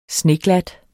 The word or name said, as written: sneglat